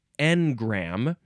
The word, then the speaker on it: Engram: No, you have to emphasize the first syllable. Engram, spelled E-N-G-R-A-M, or you can make it more French, probably, and add an additional M-E at the end. E-N-G-R-A-M-M-E. Engram.